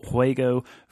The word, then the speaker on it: Juego: And that's just for those of us who don't speak great Spanish, that's Juego, J-U-E-G-O, correct? – Yes, Juego. You don't really, it's kind of different to pronounce. It's Juego.